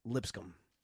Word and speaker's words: Lipscomb: I actually checked the pronunciation to make sure there wasn't something like different. It's Lipscomb.